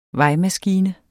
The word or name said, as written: VEJMASKINE